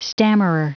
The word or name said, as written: stammerer